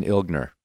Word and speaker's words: Ilgner: And one thing I found out is that you do pronounce the G in Ilgner. Just slightly. Just hit it.